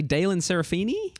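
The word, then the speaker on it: Daelyn Serafini: Moving around the table to, I hope I say this correctly, Daylan Serafini? – Oh, yeah. Yeah.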